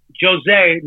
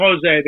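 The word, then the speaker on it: José: So enter Jose, not Jose. They don't pronounce it that way. They pronounce it Jose in Portugal, in Portuguese.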